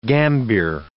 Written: gambier

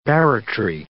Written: barratry

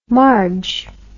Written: marg

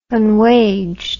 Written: unwaged

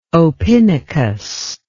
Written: opinicus

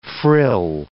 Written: frill

